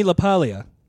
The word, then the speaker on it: LaPaglia: Anthony LaPaglia. – Do you pronounce the G though? – No, it's like lasagna.